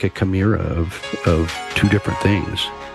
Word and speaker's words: chimera: When you take two different species and you create a new creature, a chimera. Am I pronouncing that correctly? I think so. – Yes, that's it.